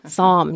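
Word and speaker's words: SOMM: I like that it's just called the Somme Journal because that's just so much easier to pronounce than the Sommelier. – Somme. Yes, that's Somme.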